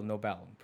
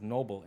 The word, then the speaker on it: Nobel: So it's spelled N-O-B-E-L, Nobel, pronounced Nobel. Most common way to pronounce is Noble in America, which is generally a last name.